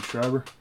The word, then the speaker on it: Schreiber: Schreiber.